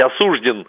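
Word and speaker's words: осуждён: Для меня Сталин может быть осужден, это я говорю как юрист, да, и даже ударение правильное поставил, по двум статьям.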